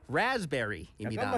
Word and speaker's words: raspberry: 네, 이 P는 거의 발음하지 않습니다. 이 Raspberry라고 하면 안 돼요. 네. – 내가 할 줄 알았죠. – 네, Raspberry. 그래서 할 줄 알았다고요. – Raz에 이 강세를 줘야 됩니다.